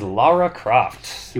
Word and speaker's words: Lara Croft: It is one of the most mispronounced names by Americans. Throwing in that O or throwing in a U for some reason and calling her Laura, but it is Lara.